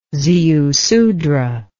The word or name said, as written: ziusudra